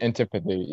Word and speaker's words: Antipathy: That's how you pronounce it, right? – Antipathy, yeah. – Antipathy, yeah. Okay. And I always think it's like antipathy or whatever. It's like bouncy.